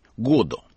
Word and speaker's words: Годо: Даже имя Годо, как мы привыкли произносить, у него звучит так, как произносил сам Беккет, с ударением на первом слоге – Годо.